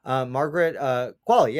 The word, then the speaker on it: Margaret Qualley: I mean, the performances are, Margaret Qualley is, wait, I've got to see if I'm saying her name right. Sorry. Margaret Qualley.